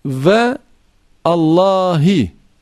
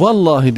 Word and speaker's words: vallahi: Yani o ilk kelimenin ilk harfi eğer hemze-i vasıl ise, mesela ve Allahi, vav sonra Allah, bu vallahi diye okunur veya vallahu neyse artık, vallahi Yani ve Allahi diye okunmaz. Oradaki Allah isminin başındaki harf elif o okunmaz yani. Direkt lama geçilir.